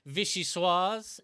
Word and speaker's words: vichyssoise: He says it's an ice-cold vichyssoise.